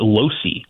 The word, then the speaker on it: Losey: I've also learned, because I was mispronouncing his name for several years, he actually pronounces it Losey, which is interesting. Not like Kyle Loesch, right, the old big leaguer.